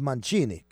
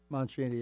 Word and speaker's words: Mancini: Now, of course, if you're a real Italian, you say Henry Mancini, okay? That's how you say his name. – Mancini, yes.